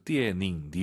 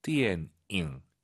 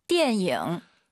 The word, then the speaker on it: 电影: 초급자분들께서 띠에닝, 니은 발음으로 많이 해서 그래요. 띠엔잉 잊지 마시고요. 한번 더 들어볼게요. – 电影.